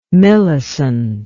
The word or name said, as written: milicent